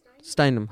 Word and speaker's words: Steinem: It is Gloria, how do I pronounce this, Madhu? Steinman? Steinem? Steinem, huh.